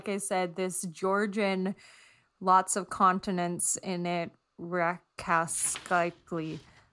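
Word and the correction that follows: Rkatsiteli: which is Arkatsateli. – Arkatsateli. I like that.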